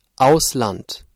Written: Ausland

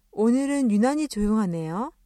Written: 조용하다

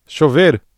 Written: chover

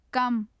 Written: kam

